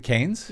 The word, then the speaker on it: Keynes: Uh, C- uh, Canes? – Yes.